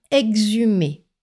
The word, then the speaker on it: exhumer: Dans les mots qui commencent par EXH, faites comme si le H n'était pas là, à l'oral seulement, parce qu'à l'écrit, il faut bien le noter. Par conséquent, les mots qui commencent par EXH se prononcent aussi eggs. Voyons cela avec quelques exemples. Le verbe exhumer, E-X-H-U-M-E-R, exhumer.